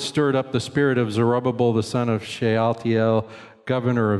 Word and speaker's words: Zerubbabel: There's Zerubbabel. It sounds like you're mispronouncing it even when you say it correctly. Zerubbabel.